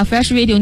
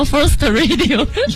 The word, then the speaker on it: Fresh Radio: 但是 Coco 这次给我们的 ID 把 Fresh Radio 念错了，念成了 First Radio。